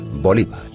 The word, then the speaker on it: Bolívar: En algunos países europeos, su apellido se acentúa en la segunda sílaba, Bolívar.